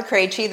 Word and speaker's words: Krejci: Well, my name is Martha Krejci. That's how you pronounce all of those consonants really close together.